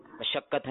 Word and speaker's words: مشقت: مشقت کا صحیح تلفظ کیا ہے؟ مشقت ہے یا مشقت ہے؟ – اچھا آپ جواب دیجیے. – مشقت ہے درست. – جی مشقت. – میم کے اوپر کیا ہے؟ – زبر.